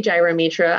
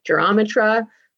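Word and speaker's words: Gyromitra: I say gyrometra. I've heard people say gyrometra. Another valid pronunciation is Eurometra, and we'll get into why.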